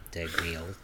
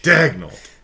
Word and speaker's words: Dagnall: Dagnall. – It's not a silent G, but it is emphasis on the first syllable. – Okay, got it. Dagnall.